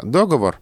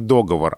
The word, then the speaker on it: договор: на распространение варианта «договор» повлияли другие слова, которые оканчиваются на «говор», где ударение на первом слоге, а именно «выговор», «заговор». Вот по этой же модели и происходит распространение ударения «договор», а когда-то было еще и «приговор».